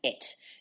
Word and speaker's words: et: And it's in Latin, it's pronounced et. In French, it's, you know, it's a silent T.